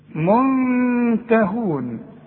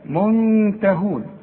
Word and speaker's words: منتهون: الته نون ساكنة وبعدها ته، منتهون، شوف منته، واخد بالك، اسمع النطق كويس، اوعى تقول لي منتهون، اوعى تضرب لسانك في حلق بقك وتقول لي من، لا منته، منتهون.